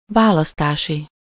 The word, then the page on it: választási: /ˈvaːlɒstaːʃi/